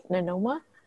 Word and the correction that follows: Nimona: Nimona. – Yeah, that's how it's spelled. – Ramona. Kind of rhymes with Ramona.